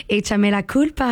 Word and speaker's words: Échame la culpa: Échame la culpa.